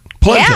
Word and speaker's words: polenta: Polenta? – Polenta, yeah.